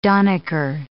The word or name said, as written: donnicker